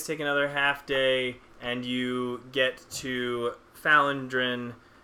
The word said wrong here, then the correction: Phandalin: Or Phandalin. You get to Phandalin.